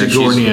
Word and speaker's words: Sigourney: Sigourney.